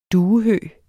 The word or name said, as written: Accipiter